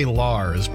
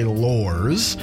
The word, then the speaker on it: Laurs: I want to say Lars, but it's spelled L-A-U-R-S, so I always say Lors.